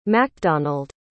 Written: makdonald

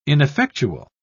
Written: ineffectual

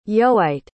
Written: yoite

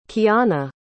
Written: kiana

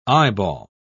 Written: eyeball

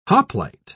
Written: hoplite

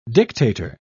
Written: dictator

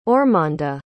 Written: ormanda

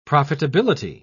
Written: profitability

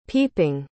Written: peeping

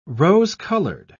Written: rose-colored

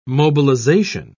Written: mobilization

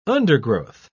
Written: undergrowth

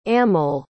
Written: amol

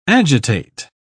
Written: agitate

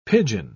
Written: pidgin

